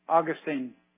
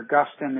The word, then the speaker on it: Augustine: Augustine had a great statement, or Augustine, if you pronounce his name that way.